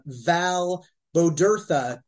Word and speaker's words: Val Bodurtha: Please welcome Val Bodurtha to the 96 Person Bringer podcast.